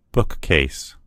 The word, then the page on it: bookcase: American English